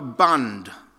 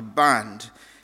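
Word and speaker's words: bandh: In Nepal, there is something called a bund. In English, it's spelled B-A-N-D-H, a bund.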